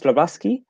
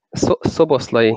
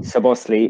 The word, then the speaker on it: Szoboszlai: Slobaski? – Szoboszlai. – Szoboszlai.